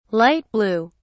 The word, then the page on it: light-blue: British English